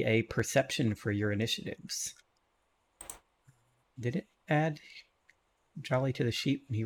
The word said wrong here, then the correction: Golly: Sorry, it's pronounced Golly.